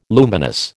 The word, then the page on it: luminous: American English